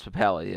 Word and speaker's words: Papale: Like Invincible, very big Philly movie, obviously about Vince Papale. Am I saying that right? Yeah. – Vince Papale, yeah.